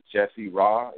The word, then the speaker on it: Jesse Ra: My Facebook name is Jesse Ra. It's spelled J-E-S-S-E, and then space and R-A.